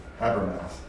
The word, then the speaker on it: Habermas: H-A-B-E-R-M-A-S. Habermas. Habermas. And Lacona, I think, is... Yeah, you can't read that. H-A-B-E-R-M-A-S.